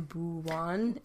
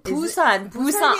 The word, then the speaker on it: Busan: I always hear, there's a accent for people that live in Busan. – Busan. – Oh my god, I pronounced it wrong.